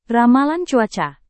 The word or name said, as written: ramalan cuaca